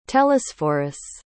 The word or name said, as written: telesphorus